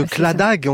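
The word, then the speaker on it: Claddagh: L'anneau de Cladagh, on prononce comme ça. – Oui. C'est ça.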